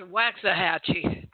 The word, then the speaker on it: Waxahachie: It's pronounced Waxahachie. – Waxahachie.